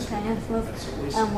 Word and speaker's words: chiasmus: So both an oxymoron and also kind of a chiasmic structure. I'm not pronouncing that right. chiasmus, where we have the man, the beast of man, this structure.